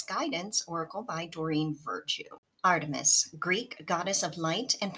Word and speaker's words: Artemis: Pronounced Artemis, A-R-T-E-M-I-S, the Greek goddess Artemis is the twin sister of Apollo, the sun god.